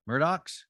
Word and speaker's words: Murdaugh: Murdochs? – Murdochs. Is it spelled like, yeah. It, but Alex is Alec and Murdaugh is Murdoch. So that's just how South Carolina rolls.